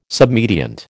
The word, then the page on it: submediant: American English